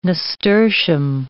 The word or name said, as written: nasturtium